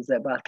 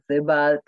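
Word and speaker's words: Sebald: L-am pronunțat numele lui Zebald, l-am pronunțat inițialele americanește. Este vorba despre scriitorul Zebald, care este scriitor european, iar PEN-ul american îl celebrează având dând numele premiului numele lui.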